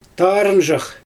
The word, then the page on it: taarɯnʒax: Xyzyl